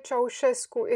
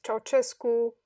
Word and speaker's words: Ceaușescu: Nicolae Ceaușescu is how you would say his name in Romanian, but usually in English, they pronounce it Ceaușescu or something of the sort.